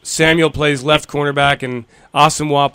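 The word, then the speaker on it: Asomugha: Asamoah, Nnamdi Asamoah, Asamoah. Asamoah.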